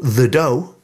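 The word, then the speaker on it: The Dø: They're called The Doe. It's spelt do, but it's pronounced doe.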